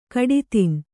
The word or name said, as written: ಕಡಿತಿನ್